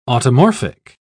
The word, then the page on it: automorphic: British English